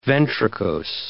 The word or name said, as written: ventricose